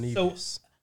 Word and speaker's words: Nevis: Saint Kitts and Nevis.